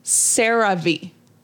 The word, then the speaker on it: CeraVe: It is pronounced CeraVe.